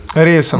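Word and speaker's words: Reyes: Ты знаешь, я, я смотрел бой э, вот с Домиником Ройс, Ройсом, или как его фамилия правильно произносится? Рейсом.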